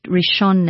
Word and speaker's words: Rishon: Or if you just put my name on on YouTube or Google, you will find it, Rishon Naftali. It's spelled, Rishon is R-I-S-H-O-N, Rishon. And Naftali is N-A-P-H, P for Peter. So it's Naftali, N-A-P-H-T-A-L-I.